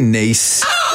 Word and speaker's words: Nice: Yeah, a Nice biscuit pronounced Nice like the name of the French city.